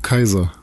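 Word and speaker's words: Caesar: Allgemeinheit ist sich nicht mehr sicher, ob es Käser oder Cäsar ausgesprochen wird. Kaiser. Deswegen ja. heißt er ja auch Kaiser. Genau.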